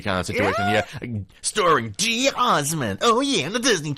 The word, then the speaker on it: geosmin: I want to say geosmin. – Geosmin. – Yes. Like, it's geosmin. – Sounds like a... – Or geosummon. Okay. Some people do pronounce it geosummon as well, or geosmin. And it's basically spelled G-E-O-S-M-I-N.